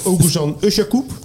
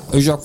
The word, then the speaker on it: Oguzhan Özyakup: Oguzhan Özyakup. – Ja. – Özyakup.